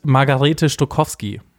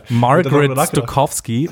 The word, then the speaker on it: Margarete Stokowski: Die heißt Margarete Stokowski. Genau, in der letzten Folge hast du das Englisch ausgesprochen. Ich habe sehr gefeiert. – Margaret Stokowski, was habe ich gesagt?